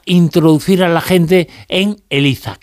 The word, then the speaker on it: ELIZA: ELIZA, y además está bien pronunciado con Z, aunque yo viva en Cádiz, no es que sea ELIZA y yo le llame ELIZA, que ya me lo han dicho por ahí, no, es ELIZA con Z.